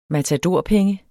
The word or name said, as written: matadorpenge